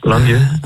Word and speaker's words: Lang Yu: Heb ik Lang Yu goed uitgesproken overigens? – Ja, dat is goed. Ja, Lang Yu.